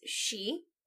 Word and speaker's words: Sidhe: But we are used to seeing the Gaelic spelling for fairy as S-I-D-H-E, and it being pronounced she.